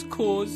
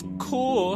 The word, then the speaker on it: Corps: But how comes it's pronounced core and not corp? It has a P at the end. P's go puh, puh. – Jacob, it doesn't matter. The E at the end of core, spelled C-O-R-E, is silent, just like the P at the end of core, spelled C-O-R-P.